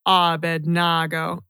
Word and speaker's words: Abednego: What syllable gets the stress? – The E and the A. Abednego. Abednego.